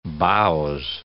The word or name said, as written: bowse